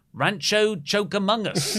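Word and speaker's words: Rancho Cucamonga: Luke, it's pronounced... Rancho Choke Among Us. Being an LA native, I can say that.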